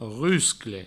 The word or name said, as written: ruscle